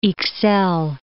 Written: Ixelles

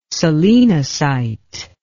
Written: solenocyte

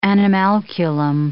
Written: animalculum

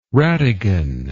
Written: rattigan

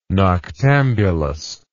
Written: noctambulous

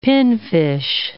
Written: pinfish

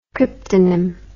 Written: cryptonymous

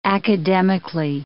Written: academically

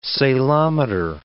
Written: salometer

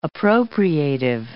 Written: appropriative

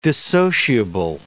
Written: dissociable

